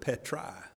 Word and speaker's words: petrai: Notice he says, then, upon this rock, and that's the Greek word petrai, P-E-T-R-A-I. And the Greek word petrai means a huge cliff.